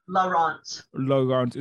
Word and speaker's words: LaRont: LaRont. – Is that French by any chance? – It is. – Oh, wow. So Marcy LaRont.